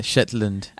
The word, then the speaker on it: Shetland: Yeah, Shetland.